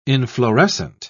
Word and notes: inflorescent: British English

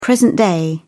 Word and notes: present day: British English

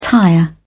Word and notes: tire: British English

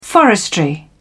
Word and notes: forestry: British English

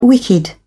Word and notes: wicked: British English

